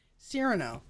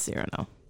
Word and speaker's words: Cyrano: Little Serrano. – Little Serrano. The little seasoning. – I said it wrong again. Cyrano. – It's Cyrano.